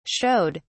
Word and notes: showed: British English

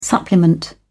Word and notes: supplement: British English

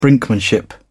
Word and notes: brinkmanship: British English

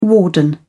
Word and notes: warden: British English

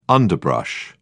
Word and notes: underbrush: British English

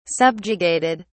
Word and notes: subjugated: British English

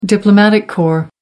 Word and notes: diplomatic corps: American English

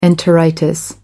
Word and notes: enteritis: American English

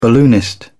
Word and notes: balloonist: British English

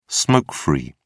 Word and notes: smoke-free: British English

